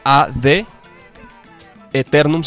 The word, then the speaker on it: Ad Aeternum: pero es Ad Eternum, se escribe Aeternum, ¿ok? Ad Aeternum.